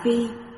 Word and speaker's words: bị: Chữ bị ở đây đọc là phi, làm động từ, giống bột thủ ở bên, tức trùm lên cơ thể.